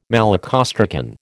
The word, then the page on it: malacostracan: American English